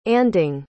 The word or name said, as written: anding